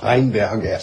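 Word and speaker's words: Rheinberger: Je vais d'ailleurs demander à un Allemand de me le prononcer, puisque vous pouvez me dire comment on prononce ce nom de votre compatriote. – Ah oui, ouais. Pour Reinberger. – Ça. Alors j'avais bien prononcé, voilà.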